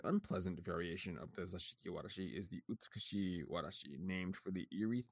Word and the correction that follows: Usutsuki: Oh, I'm mispronouncing that word. So, Usutsuki. There we go. Okay. I was saying beautiful, which was not making any sense.